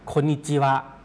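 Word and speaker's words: โ คนิ จิ วะ (こんにちは): ปุ่นเขาก็จะใช้สำนวนนี้นะครับสำนวนนี้ออกเสียงว่าโคนิจิวะครับโคนิจิวะออกเสียงช้าๆชัดๆนะครับจะออกเสียงว่าโคอึครับโคกับอึเนี่ยนะครับออกเสียงรวมกันก็เป็นคนครับคนนิจิครับโลวันจิตัว CHI ตัวนั้นนะครับออกเสียงว่าจิจิครับแล้วก็ WA ก็วะนะครับโคนิจิวะโคนิจิวะครับตัวคนนี้ออกเสียงยาวนิดนึงนะครับ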